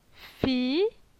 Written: Fi

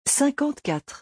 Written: 54

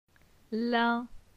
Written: Lin

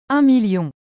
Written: 1000000

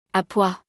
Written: A pois

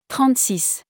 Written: 36

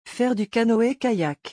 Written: faire du canoë-kayak

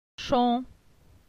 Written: Chon

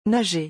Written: Nager

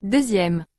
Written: 2e

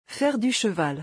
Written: Faire du cheval

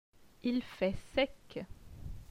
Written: Il fait sec

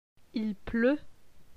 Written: Il pleut

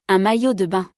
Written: Un maillot de bain

